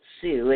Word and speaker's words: Tsou: His name is Andy Sue, and that's spelled T-S-O-U.